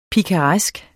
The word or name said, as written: pikaresk